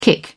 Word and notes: kick: British English

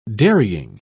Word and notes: dairying: British English

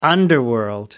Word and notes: underworld: American English

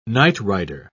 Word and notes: nightrider: British English

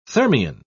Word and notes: thermion: British English

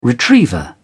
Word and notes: retriever: British English

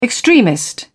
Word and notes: extremist: British English

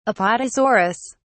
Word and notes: apatosaurus: British English

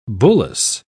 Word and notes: bullace: British English

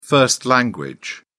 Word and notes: first language: British English

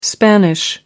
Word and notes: Spanish: American English

